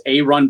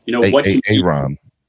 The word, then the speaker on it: Aaron: So, A-Run, you spell it Aaron, but it's pronounced A-Run Brewer. – A-Ron.